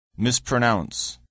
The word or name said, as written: mispronounce